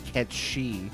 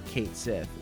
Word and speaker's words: Cait Sith: I'm never going to say Ketshi for Kate Sith when I'm playing Final Fantasy VII. – No. – But we're just saying, like, that's the original pronunciation for that reference, at least.